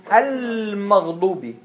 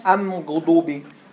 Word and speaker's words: المغضوب: Because what? المغضوب. You don't say أغضوب. – Oh, I thought you said. – أمغضوب. You can't even pronounce it. أمغضوب. No. المغضوب. No, it doesn't work. So it's المغضوب.